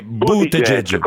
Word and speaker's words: Buttigieg: Ma come si pronuncia Francesco Costa? In realtà è Buttigieg.